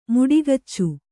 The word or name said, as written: ಮುಡಿಗಚ್ಚು